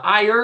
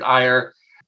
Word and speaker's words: Eyer: and then, of course, we have the genie played by Richard uh, Iyer, or uh, yeah, I'm pretty sure that's how it's pronounced, Richard Eyer.